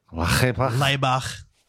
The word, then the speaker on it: Laibach: Laibach. – They're a Slovenian industrial band. – Can you spell that for me? – Yeah, it's a L-A-I-B-A-C-H. Laibach. From the album is NATO, N-A-T-O, from 1994.